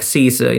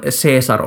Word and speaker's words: Caesar: Siis riippuu, miten haluat latinasi ääntää. Caesar on pehmeällä latinalla like Caesar in English, mutta minä äännän klassista kovaa latinaa, mutta varmaan Caesar olisi lähempänä Shakespearen omaa ääntämystä.